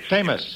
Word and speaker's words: Tsamis: Thame? No, it's... Samus? – Famous. T-S-A-M-I-S.